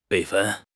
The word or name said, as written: 备份